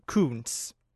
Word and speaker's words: Kuntz: Uh, han har då egentligen då förklarat att det ska uttalas Coons. Men stavas K-U-N-T-Z.